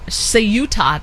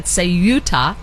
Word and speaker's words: Ceuta: So let me just give you a little bit of a background before we're joined by Hugo, just about where Ceuta, that's how they say it, Ceuta is the part of Spain that is located on the African coast.